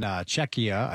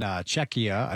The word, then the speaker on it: Czechia: Then it's South Korea and Czechia, I believe that's how you say it.